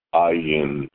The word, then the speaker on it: ayin: So how do we pronounce that? Ayin. Ayin. Ayin. Except that, as I said, Americans, our throats are not trained to make the sound. And in fact, the Ashkenazis, the German Jews, but really the European Jews, don't make the sound properly either.